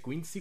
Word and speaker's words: Quincy: Bueno, mientras tanto, dice por acá, buenas tardes al pastelazo, saluditos desde, ¿cómo se dice? Quincy, Washington, así se pronuncia.